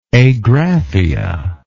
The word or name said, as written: agraphic